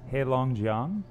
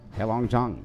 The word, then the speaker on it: Heilongjiang: You've previously been a teacher at the Heilongjiang Institute of Technology in Harbin, China. Did I say that right? – Close enough. – How do you actually say it? – Heilongjiang. – Heilongjiang.